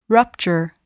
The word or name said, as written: rupture